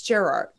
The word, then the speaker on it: Gerard: How do you say it? It's like super French, right? Gallant? Or is it Gerard? No, it's Gerard.